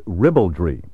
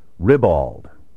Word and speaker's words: ribald: Some speakers have adopted the indelicate spelling pronunciation, ribald, and certain dictionaries that cater to the gross whims of the vulgar masses now record ribald. I urge you to eschew this unrefined variant, and also to avoid the equally uncultivated ribald. There is no rye, and there is no bald, in ribald. The word should rhyme with scribbled and dribbled.